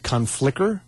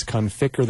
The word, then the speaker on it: Conficker: And some people pronounce it Conflicker. And it's not. It's Conficker. There's no L in there.